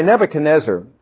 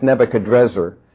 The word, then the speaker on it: Nebuchadnezzar: By the way, Nebuchadnezzar, often pronounced Nebuchadrezzar, means Nabu has protected the succession rights.